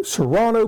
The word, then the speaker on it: Serrano: Serrano pepper.